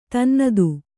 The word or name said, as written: ತನ್ನತು